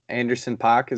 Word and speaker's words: Anderson .Paak: Anderson .Paak, is that how you say it? – Yeah.